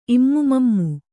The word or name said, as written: ಇಮ್ಮುಮಮ್ಮು